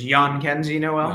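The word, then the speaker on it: Jhonkensy: I always thought it was John Kenzie Noel until he was in the Futures game and learned it was Jan Kenzie Noel.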